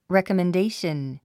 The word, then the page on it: recommendation: rèkəmendéiʃən; レコメンデーション